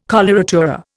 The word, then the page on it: coloratura: Indian